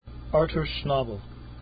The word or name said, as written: schnabel